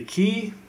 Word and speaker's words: Qi: For the longest time, I would say Qi because it's, I think it's literally Q. Is it QI? – It's QI, yeah.